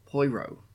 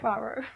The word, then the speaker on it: Poirot: Hercule Poirot. Okay. – Poirot. – I told you my pronunciation is not good.